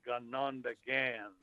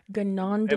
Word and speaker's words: Ganondagan: The pronunciation is Ganondagan. – Ganondagan.